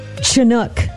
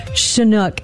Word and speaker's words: Chinook: I said Chinook instead of Chinook.